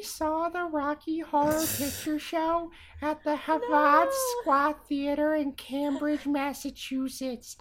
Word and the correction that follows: Harvard Square: How do I say that? – Harvard Square Theater.